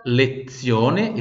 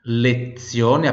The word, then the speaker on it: lezione: Le forme geométrica, terça parte, repita, lezione, esse Z tem som de TS, lezione,